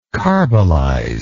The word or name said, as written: carbolize